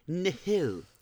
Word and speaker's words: Nihil: They are targeted by the Nil. Did I say that right? – Nile. – Nile? Okay. – Yeah. – It's Nihil.